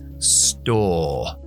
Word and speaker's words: store: Tienda, que para mí la palabra es shop, pero la palabra estadounidense es store. Store. Pronunciación estadounidense sería store. Store. Escucháis esa R ahí al final, store.